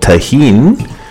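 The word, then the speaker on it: Tajín: It's called tahin. It's spelled T-A-J-I-N, right? – Correct.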